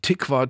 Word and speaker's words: Tikva: Tatsächlich haben zwei Theater in Berlin ein Jahr lang zusammengearbeitet, nämlich das Ensemble vom Tikva Theater in,